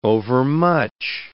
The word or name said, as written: overmuch